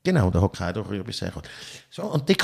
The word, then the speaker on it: Hokkaido: Es heißt nicht dann würde es H-O-K-K-A-I-I-D-O heißen, wenn es Hokkaido würde heißen. Aber es heisst Hokkaido. Mein Gott, ich habe das immer falsch gesagt.